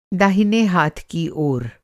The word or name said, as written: दाहिना